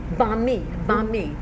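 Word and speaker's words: bánh mì: First time I've ever had a benmai in benmai, how do you say it? Benmai? Banh mi.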